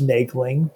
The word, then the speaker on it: Moegling: My last name is pronounced Meagling, but it's spelled like Mogling.